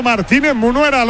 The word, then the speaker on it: Martínez Munera: Ha pitado Martínez Munera. Lo he dicho bien, Pedro. – Perfecto.